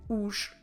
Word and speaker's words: os: and that will be os.